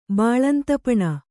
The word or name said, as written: ಬಾಳಂತಪಣ